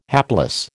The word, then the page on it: hapless: American English